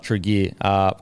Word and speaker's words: Tregear: So, that's T-R-E, Trigear. Trigear, maybe. So head into IGA Liquor Trigear. It's spelled T-R-E-G-E-A-R. You boys know how to say that? – No, sorry. – Trigear. IGA Liquor Trigear.